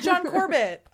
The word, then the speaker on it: John Corbett: And John Corbett.